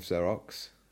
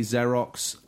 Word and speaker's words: Xerox: a research arm of Xerox. Are we saying that right? – You can say Xerox. I would say Xerox.